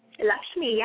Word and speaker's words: Lakshmi: Lakshmi chant? – Lakshmi, yeah.